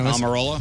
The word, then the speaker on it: Almirola: Almirola.